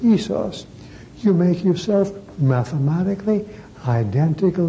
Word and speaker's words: isos: Isos? – Well, it's pronounced isos. Isos. Isos in Greek. I-S-O-S.